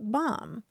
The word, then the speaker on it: BOM: The acronym for it is BOM, pronounced bomb.